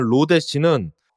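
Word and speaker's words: lodash: 그리고 방금 얘기한 로데시는 스펠링은 lodash에서 로데시지만 뭐 의미상으로 보면 로우데시 그러니까 이제 대시 기호가 아래에 붙어 있는 대시 기호를 얘기한다고 볼 수 있죠.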